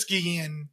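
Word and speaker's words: Guillén: Guillen. – In Spanish, it's Guillen. But all right.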